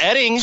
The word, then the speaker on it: Oetting: Mark Oding. – Hold on. It's pronounced Edding. – But it's spelled O-E-T-T-I-N-G. – Yes, and O-E-T-T-I-N-G. It's pronounced Edding. Take two.